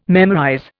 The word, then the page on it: memorize: Indian